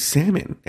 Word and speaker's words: salmon: Because we're like, because they said everybody at their school says salmon. And I was like, no, it's salmon.